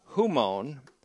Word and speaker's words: humon: Next is the genitive preposition peri, or peri, P-E-R-I, peri, which means concerning or about. And then the personal second person pronoun, humon, which is H-U-M, long O-N, H-U-M-O-N, humon, meaning you, or as they say down south, you all or y'all.